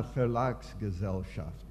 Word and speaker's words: Verlagsgesellschaft: Und dass ich eigentlich zusammenarbeiten darf mit dieser Verlagsgesellschaft in Dillenburg. – Yeah, Verlagsgesellschaft.